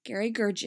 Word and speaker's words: Gergich: To Jerry Gergich. Gergich. It's Gergich.